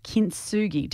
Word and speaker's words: kintsugi: And a concept that you work out with your coaching is this idea of kintsugi.